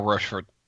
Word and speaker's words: Rochefort: and touch on the history of the Roquefort? Is that how it's said? Rochefort? – I think it's more Rochefort. Rochefort, I think, is the way it is. At least that's the French pronunciation, and I believe it's the French part of Belgium.